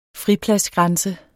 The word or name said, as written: fripladsgrænse